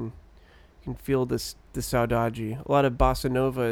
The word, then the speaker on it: saudade: You know this uh, this word saudade? Am I saying it right? Yeah. Mm-hmm.